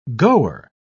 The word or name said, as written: goer